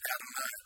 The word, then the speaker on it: Adonai: But we were taught that when you had Hebrew students, we were taught as Hebrew students, it's whenever you came across it, you pronounced it Adonai.